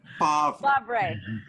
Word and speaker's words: Favre: F-A-V-R-E is pronounced Favre.